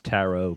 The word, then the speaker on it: tarot: Or I think it's pronounced tarot card. – Tarot card? – Yeah. – Oh, it's a soft T.